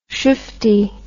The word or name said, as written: shufty